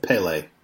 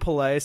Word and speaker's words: Pelé: Put the wrong emphasis on the wrong syllable. – Well, no. Some of the Brazilians call him Pelé. Some of them call him Pelé. Like, it all depends on, you know, where you're from.